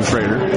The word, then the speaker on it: Schroeder: And it's pronounced Schrader.